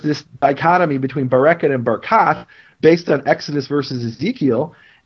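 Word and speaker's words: Barkat: But so the mayor of Jerusalem, he pronounces his name Barakat the way it's pronounced in Ezekiel, according to this ancient pronunciation tradition. Most people in Jerusalem that I know will call him Bereket. And why would they call him Bereket? They'll mispronounce his name.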